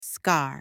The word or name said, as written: scar